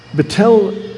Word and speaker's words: Betel: Betel means Bethel. It's a Spanish form of the house of God. You don't pronounce the H in Spanish.